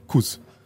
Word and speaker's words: Kuss: Wir müssen über Sepp Kuss sprechen. Kuss. Ich glaube Kuss, weil er hat deutsche Vorfahren auch. – Ja, aber selber sagt er möchte Kass ausgesprochen werden.